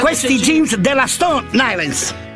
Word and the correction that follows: Stone Island: Stone Island. – Ma perché scrivono? Scrivono come si legge. – Si chiama così, Stone Island.